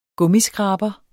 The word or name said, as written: gummiskraber